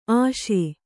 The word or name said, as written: ಆಶಿ